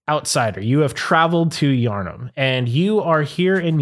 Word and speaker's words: Yharnam: Y h a r n a m. Yarn. Yharnam. – Yharnam. – Yharnam. It's like Putnam.